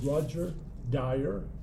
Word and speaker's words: Roger Dyer: My name is Roger Dyer, R-O-G-E-R-D-Y-E-R.